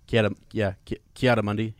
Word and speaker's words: Ki-Adi-Mundi: Ki-Adi-Mundi.